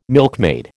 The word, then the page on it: milkmaid: American English